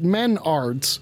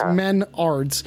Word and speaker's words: Menards: Okay, it's some people may pronounce it Menards, but Menards is a Midwest, upper Midwest, like Home Depot-esque sort of place. You know, Menards. It's not Menards. – Okay. – It's Menards.